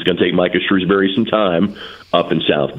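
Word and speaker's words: Shrewsberry: I think Notre Dame will be a tremendous program under Micah Shrewsbury in the future.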